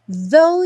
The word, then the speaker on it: though: Smile, though your heart is aching. Bom, though, although, even though, todas essas palavras que terminam com GH, o GH não vai ser pronunciado, então não se preocupe com ele.